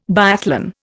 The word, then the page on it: biathlon: Indian